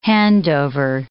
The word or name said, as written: handover